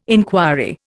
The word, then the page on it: enquiry: Indian